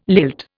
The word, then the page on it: lilt: Indian